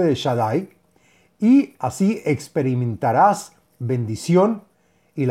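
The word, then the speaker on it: Shaddai (שדי): Trae un nombre sagrado para meditar y son las letras Shin Dalet Yud, que se pronuncia Shaddai y que es el nombre de Dios en las Firayesod.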